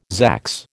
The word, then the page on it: zax: American English